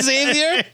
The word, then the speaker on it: Xavier: You mean Professor Xavier? It's only been pronounced Xavier in every cartoon and movie he's ever appeared in.